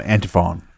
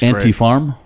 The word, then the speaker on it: Antiphone: It's Antifarm? Great. – Antiphone. Like Antiphone, it's spelled. Oh, okay. Antiphone. It's Antiphone or yeah. Farm? What are you saying? – Phone. Antiphone. – Phone? – Phone, not farm.